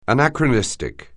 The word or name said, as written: anachronistic